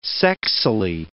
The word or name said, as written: sexily